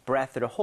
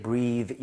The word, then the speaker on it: breath: 그래서, 본인의 breath를 hold 한다는 것은 이거는 숨을 참다가 되겠습니다. – 네네네. – 그리고 이게 breathe라는 동사가 아니에요. 그죠? 이 숨이라는 명사란 말이에요. 그래서 발음할 때 breathe 이게 아니라 breath.